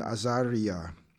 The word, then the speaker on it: Azariah: Among these from the people of Yehuda were Daniel, Hananiah, Mishael, and Azariah. That's how you pronounce their name in Hebrew.